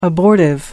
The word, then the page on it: abortive: American English